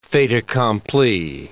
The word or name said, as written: fait accompli